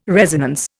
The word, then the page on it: resonance: Indian